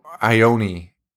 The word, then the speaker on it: Ione: Oh man, and he just like, and his sister is Ion. I don't know how to pronounce her name. Ion Sky. She's the girl in "Say Anything." – John. Oh, Ione. – Ioni Sky. That makes sense.